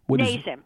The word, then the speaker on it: NASEM: How do you pronounce that? NASEM? – NASEM, N-A-S-E-M, the mm-hmm.